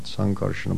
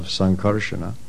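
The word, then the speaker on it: Saṅkarṣaṇa: This Rudra, who is known as Sankarsana, with a long A, not Sankarsana, but Sankarsana, which means the derived from or son of Sankarsana.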